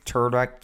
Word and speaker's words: Tudyk: I can't pronounce Alan Tudyk? – Tudyk, yeah.